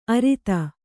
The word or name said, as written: ಅರದ